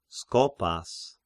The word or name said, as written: Skopas